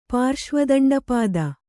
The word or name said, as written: ಪಾರ್ಶ್ವದಂಡಪಾದ